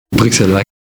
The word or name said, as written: bruxellois